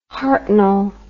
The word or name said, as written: hartnell